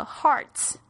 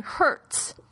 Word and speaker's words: hearts: Bottom of our hearts，这边我们要特别注意到，我觉得有很多我的学生呢，他会把这个 hearts 念成 hurts，这样子的话，这个就差很多了。